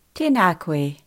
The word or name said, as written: tena koe